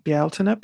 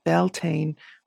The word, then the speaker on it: Bealtaine: I get a lot of pushback around Bealtaine because in Scotland they do say it Beltane. But if you are working within a so-called Celtic tradition, most often the Beltane pronunciation is actually an anglicized version because people can't say Bealtaine or don't want to say Bealtaine because it has too many vowels, as I was told one time.